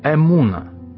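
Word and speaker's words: эмуна (אמונה): В еврейской энциклопедии слово «вера» в религиозном смысле означает или произносится как слово «эмуна».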